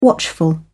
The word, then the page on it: watchful: British English